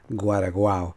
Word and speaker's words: Guaraguao: I think I had mispronounced it when I first presented the song, Guaraguao.